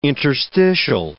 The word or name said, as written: interstitial